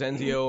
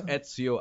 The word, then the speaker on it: Ezio: Everybody always says Enzio or Enzo. It's Ezio Auditore da Firenze.